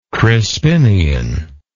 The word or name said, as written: Crispinian